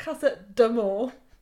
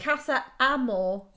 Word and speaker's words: Casa Amor: Because for people who don't watch this, it's Casa Amor, darling. It's not Casa d'Amor. It's just Casa Amor. It doesn't have a D and an apostrophe. Casa Amor. – It should be Casa. – It's A-M-O-R as well.